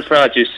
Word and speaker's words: Farage: You know Nigel Farage's surname? Farage. – F-A-Rage, yes. – Yeah. Oh, nice. – That's right. Do you know why it's not pronounced like garage? – No.